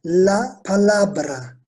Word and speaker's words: palabra: Kelime kelimesi la palabra. La palabra. Bizdeki palavraya benziyor. Van'ın V'si yerine Bursa'nın B'si. La palabra kelime.